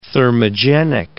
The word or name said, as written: thermogenic